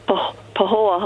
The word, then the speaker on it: Pahoa: Pahoa, Hawaii, if I'm saying that right. – Uh, it's Pahoa, Hawaii.